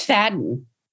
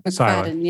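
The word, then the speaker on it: Macfadyen: You know how to pronounce that. – I think it's Fadden. – Oh, yeah. So the Y is silent. – McFadden, yeah.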